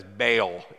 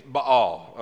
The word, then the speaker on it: Baal: Oftentimes it's mispronounced by us Americans as Baal. It's Baal, okay?